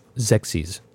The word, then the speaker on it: Xexyz: XEXYZ. How would you pronounce that? – Zexies. – Zexies? – Yeah. – He's really Zexies. – That's the official pronunciation.